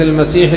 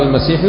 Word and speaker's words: المسيح: المسيح الدجال والمسيح عيسى كلاهما يقال له المسيح، لكن أبو داود هنا يقول المسيح الدجال يثقل والمسيح عيسى يخفف. كيف ينطق؟ بتشديد ماذا؟ السين. يقال المسيح، المسيح الدجال على هذا القول، والمسيح عيسى.